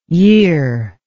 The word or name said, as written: yirr